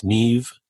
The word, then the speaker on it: Niamh: And if you want to get in touch with her, it's Neve. Neve is spelled N-I-A-M-H, and last name Paul, P-A-U-L.